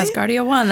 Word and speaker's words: Asgardia: Asgardia 1, did I pronounce that right? – Asgardia 1, that's right.